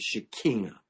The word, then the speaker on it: Shekinah: But the Shekinah, we say Shekinah, the proper Hebrew pronunciation is Shekinah.